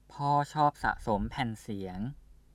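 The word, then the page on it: แผ่นเสียง: Thai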